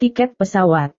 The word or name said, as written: tiket pesawat